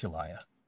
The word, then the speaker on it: Eliah: And I'll just say, it's Eliah.